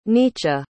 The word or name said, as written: Nietzsche